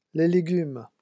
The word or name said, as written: les legumes